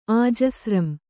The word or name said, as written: ಆಜಸ್ರಂ